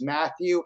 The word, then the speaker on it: Matthew: So I would suggest if you wanted to search it, you could search my name, which is Matthew, M-A-T-T-H-E-W, middle initial A as in alpha.